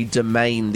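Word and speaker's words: Demesne: And I called it Santry Demence. That wasn't great. It's Santry Domain. The S is silent.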